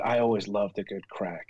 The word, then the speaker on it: craic: They call it the crack of a bar. And the crack, as they refer to it, is spelled C-R-A-I-C.